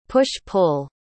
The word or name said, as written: push-pull